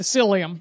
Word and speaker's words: psyllium: I take the psyllium. – Yes. – But psyllium. So, is that how you say it? Psyllium? – Psyllium. P-S-Y.